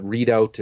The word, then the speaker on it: Redoubt: Yeah, it's called At the Readout, but Readout is spelled R-E-D-O-U-B-T.